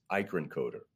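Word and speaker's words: Eikerenkoetter: So you tell me how to say it correctly, please. – Thank you. It's Eichenkoter. – Eichenkoter.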